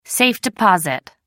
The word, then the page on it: safe-deposit: American English